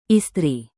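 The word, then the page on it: istri: indonesian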